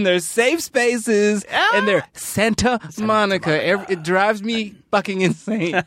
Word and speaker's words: Santa Monica: And it's we all have agreed to not pronounce the T. It takes too much time. – Sure. – We're not fops. We're not assholes. We say Santa Monica like a normal American.